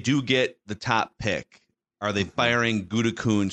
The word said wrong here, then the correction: Gutekunst: She's got to be careful the way you pronounce that with the FCC lurking over uh, ESPN Milwaukee. It's Gutekunst. Gutekunst.